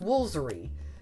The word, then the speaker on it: Woolfardisworthy: But I mean, some of them are just, I'm sorry, like Woolfardisworthy. I mean, that's how it's spelled. And it's pronounced Woolsery. There's, you've lost Fardis in there and Worth. You've lost three syllables.